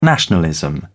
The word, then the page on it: nationalism: British English